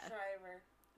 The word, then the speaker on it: Schreiber: I'm going to say Liev Schreiber, but I don't think that's how you say it. Yeah. – Schreiber.